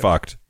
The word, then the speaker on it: FUCT: Got to be pronounced okay. fucked, right? That is the brand. – F-cut. Okay. – No, FUCT. It looks like fucked.